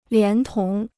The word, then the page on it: 连同: lián tóng